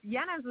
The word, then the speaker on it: Yanez: Yanez was pretty good. It just depends on where you're from, if you're pronouncing it in the Spanish way or the American accent.